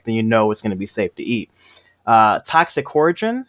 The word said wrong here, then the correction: Toxicodendron: I'm definitely saying the Latin name wrong. – Toxicodendron. I know that because I've talked a lot about poison ivy.